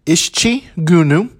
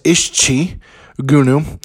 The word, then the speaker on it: İşçi Günü: This week, the holiday is known as İşçi Günü. That's İşçi Günü, and it is spelled with a I, with a dot in Turkish, and then there's an S with a little squiggle on the bottom, a C with a little squiggle on the bottom, and another I. And so that is how you spell that if you wanted to know in Turkish.